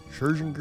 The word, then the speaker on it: Scherzinger: How do you say Scherzinger? Scherzinger? – That sounds about right.